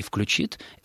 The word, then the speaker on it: включит: Но, еще раз подчеркну, до тех пор, пока жив последний образованный человек, который помнит, что нужно говорить «звонишь» и «включит», это будет единственно правильными акцентологическими вариантами.